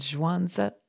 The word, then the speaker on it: Zhuangzi: And like Lao Tzu, Zhuangzi, it's actually pronounced Lao Tzu and Zhuangzi. They added that little Z-I.